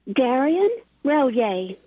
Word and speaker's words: Derrien Relyea: Derrien Relyea.